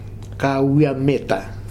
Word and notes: Kawiameta: Futuna

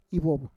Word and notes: ivov: Roon

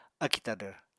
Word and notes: akì tadvr: apatani

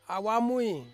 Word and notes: awamui: Ansus

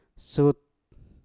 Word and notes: sut: mpur

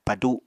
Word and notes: padu: Apatani

